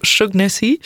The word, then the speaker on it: Shaughnessy: Attends, j'essaie. Charles Chogonensi. – Voilà, Charles Shaughnessy, qui joue donc Monsieur Sheffield.